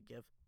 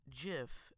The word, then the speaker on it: GIF: And I quote, he said, it's pronounced JIF, not GIF. So that is the correct pronunciation.